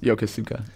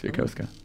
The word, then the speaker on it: Yokosuka: Yeah, I found out the hard way that it is not pronounced Yokosuka. It's Yokosuka.